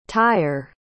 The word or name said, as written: tyer